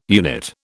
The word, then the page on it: unit: British English